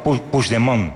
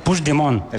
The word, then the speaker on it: Puigdemont: No, Puigdemont. – Puigdemont. – Vale. – Puigdemont. – Eso es. – Sí, lo dices como francés, así. Tiene Puigdemont, como parece como una isla.